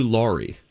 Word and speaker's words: Laurie: I would say Hugh Laurie. – Laurie. – I know, it's spelled L-O-R-R-I-E. – No, it's L-A-U. – It's L-A-U-R-I-E.